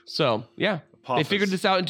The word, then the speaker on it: Apophis: Apophis. That's Apophis.